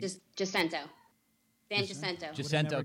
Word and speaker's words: San Jacinto: Is it Joaquino? How do you say... – San Jacinto College in Pasadena, Texas.